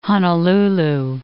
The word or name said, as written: Honolulu